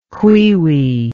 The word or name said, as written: cuiui